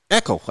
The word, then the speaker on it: Echele: How you pronounce that last name, John? – Echele.